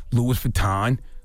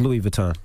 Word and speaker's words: Louis Vuitton: Who, you know, people blow 200 racks in Barney's, Gucci, Louis Vuitton, Bloomingdale's. – That's Louis Vuitton.